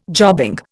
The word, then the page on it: jobbing: Indian